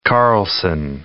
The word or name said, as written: Carlsson